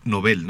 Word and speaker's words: Nobel: Yo no hablo sueco ni de chiste, pero siempre he tenido la duda y revisé, parece que es aguda la pronunciación correcta. – Sí. – Aunque está ya tan difundida la pronunciación grave que ya creo que están aceptadas las dos, aunque la original es Nobel.